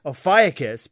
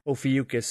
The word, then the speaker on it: Ophiuchus: I'd like to pronounce this... – Ophiuchus, but some people were pronouncing it Ophiuchus, so whatever.